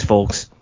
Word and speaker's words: folks: largely due to these folks. – More likely or less likely with Dexter Lumis? – I want to actually concentrate on the fact that actually pronounces the L in folks.